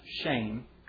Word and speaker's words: Shem: And it's appropriately pronounced shame, but it means the opposite.